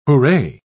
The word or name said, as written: hooray